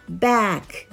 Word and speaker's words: back: この発音なんですが back b a c のあですねこれはあとえの間の音です back back back になりますで、